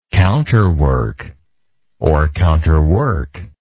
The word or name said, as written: counterworker